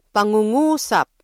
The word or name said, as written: pangungusap